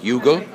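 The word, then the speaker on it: Youghal: It's sort of like, you know, there's a place in Ireland, and the name of it is spelled Yughal. Would you know how that is actually pronounced? – Yughal. – Right. – It is Yughal. – And if you call it Yughal, which rhymes with Google, Irish people will wet themselves laughing, right? – Okay.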